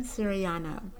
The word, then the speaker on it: Siriano: Kristen Siriano.